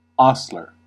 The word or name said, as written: seyis